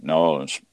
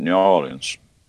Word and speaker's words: New Orleans: yeah, New Orleans. We always pronounce it New Orleans. – Well, if when you're from down there, it's one word, N-A-W-L-I-N-S. – Yeah, well, that ours had a little New Orleans, you know, kind of like New Orleans.